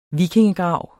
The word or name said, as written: vikingegrav